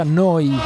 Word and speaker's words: neu: si scrive neu si pronuncia noi e vuol dire nuova nuova emittente urbana perso il conto, credo sia la puntata numero 29.